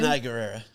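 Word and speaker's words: Danai Gurira: Denai Guerra.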